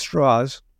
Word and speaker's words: Strauss: Strauss, spelled Strauss but pronounced Strauss, was the former head of the Atomic Energy Commission.